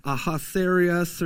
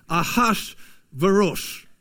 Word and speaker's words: Ahasuerus: That's not how I would normally pronounce that, right? I think most of us would read it as like Ahasuerus or something like that. But actually, like the Hebrew, how you're, my best attempt at it is Ahasuerus.